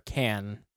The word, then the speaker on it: Cannes: I was very, very excited and interested in this movie up until what I saw the reaction was at the Cannes Film Festival. – Yeah. – Or Cannes. I guess I think the S is silent on that.